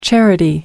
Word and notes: charity: American English

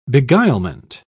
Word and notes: beguilement: British English

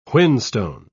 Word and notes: whinstone: British English

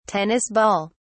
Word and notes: tennis ball: British English

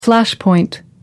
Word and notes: flashpoint: American English

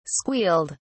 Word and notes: squealed: British English

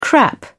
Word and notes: crap: British English

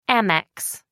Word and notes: amex: American English

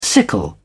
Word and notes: sickle: British English

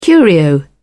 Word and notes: curio: British English

